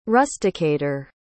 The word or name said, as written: rusticator